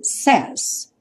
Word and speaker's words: says: Se quisermos dizer ele diz ou ela diz, vamos usar então o says, ou seja, o verbo say vai ganhar um S, mas a pronúncia muda bastante. Says.